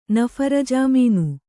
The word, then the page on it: ನಫರ್‍ಜಾಮೀನು: naphar jāmīnu